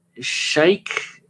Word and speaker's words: Shaikh: So in the chat box, first person, there was Adil Sheikh, S-H-A-I-K-H.